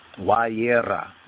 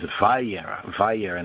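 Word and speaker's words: Wayera (וירא): Wayera, we have Wayera. Wayera, some of the other Yehudim, and they pronounce it as Vayera in their particular enunciation,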